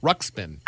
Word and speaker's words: Ruxpin: Oh, I remember the Christmas that I got my Teddy Ruxpin, or I don't know how to say his name. – Ruxpin. – I got it right.